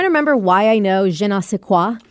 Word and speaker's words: je ne sais quoi: It's like, I got a certain je ne sais quoi about me.